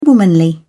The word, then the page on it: womanly: British English